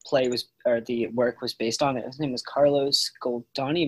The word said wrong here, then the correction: Carlo Goldoni: Am I pronouncing that right? – Carlo Goldoni, yeah.